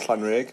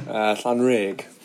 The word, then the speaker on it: Llanrug: I live up in North Wales in a little village called Llanrug near Caernarfon. – Pronounce that again. – Llanrug.